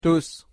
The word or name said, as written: tus